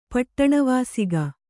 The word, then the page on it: ಪಟ್ಟಣವಾಸಿಗ: Kannada